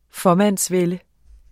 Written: fåmandsvælde

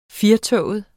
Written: FIRETOGET

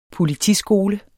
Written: politiskole

